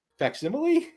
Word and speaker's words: facsimile: Facsimile? – Is that how that's pronounced? – Yes.